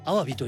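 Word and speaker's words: 鮑: 魚辺に包むと書いてアワビと日本語では読みます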